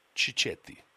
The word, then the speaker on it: cicchetti: Am I saying it right? – You could... You know, you're not. – No. So, yeah, that's it. I can tell. I just hang about. I'm doing it for myself. Ow! Okay, I'll slap myself. Say it properly for me. – Cicchetti. – Cicchetti. Okay. – Yeah. – Better? – Perfect.